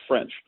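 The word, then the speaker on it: Ffrench: And then Maurice French, two F's, one capitalized F, one small F, but it's pronounced French.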